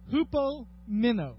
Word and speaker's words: hupomeno: It's called hupomeno. All right? Hupomeno. Now, that's obviously not it in the Greek. That's me spelling it so you can pronounce it. I want you to say it with me, okay?